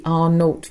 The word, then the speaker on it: R0: Every infectious disease is given a reproduction number, which is known as RO, pronounced R0, actually.